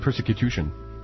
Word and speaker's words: persecution: I think you mean persecution. – Yeah. I believe you put the emphasis on the wrong syllable again.